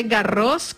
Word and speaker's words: Garros: Ahí va la primera casterita, se dice Garros con S al final porque es nombre propio. Sí, en francés la S no se pronuncia, pero está sí.